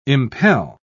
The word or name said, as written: impel